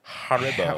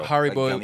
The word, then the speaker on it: Haribo: Is it Aribo? – Point. – Haribo? – Haribo. Haribo.